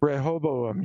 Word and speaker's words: Rehoboam: Rehoboam? Is that how you say it? – Rehoboam, yeah.